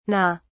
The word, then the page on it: NGA: British English